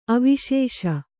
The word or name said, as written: ಅವಶೇಷ